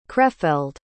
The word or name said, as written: krefeld